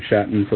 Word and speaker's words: Shatton: Greg Shatton for the record. It does not rhyme with Satan, though some might go that way.